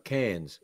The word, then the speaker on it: Cairns: And I was born in the town of Cairns, spelled C-A-I-R-N-S, but the R is silent like the Z in potato.